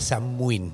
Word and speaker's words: Samhain: Se escribe Samhain, pero se llama Samhain. Se, se dice Samhain.